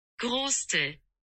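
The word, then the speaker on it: Gröstl: Grostel. That's how you pronounce it.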